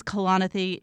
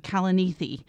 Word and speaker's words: Kalanithi: So it is Kalanithi. Someone has introduced the mnemonic device Kalanic. I don't know if we really want to go there, but it is Kalanithi, not Kalanithi.